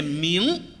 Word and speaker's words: 明: 行行行行，明儿我改。 – 什么叫明儿啊？ – 啊，规范的读音念明，电影明星有说电影明星的吗？ – 今儿算我倒霉。